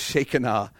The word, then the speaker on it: Shekinah: That's right, if you grew up Pentecostal, we've all been saying it wrong. It's not Shekinah, it's pronounced Shekinah.